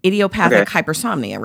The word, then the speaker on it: idiopathic hypersomnia: I'm going to say what I think it is, and if I say it wrong, idiopathic hypersomnia, right? – That's it. – I said it correctly.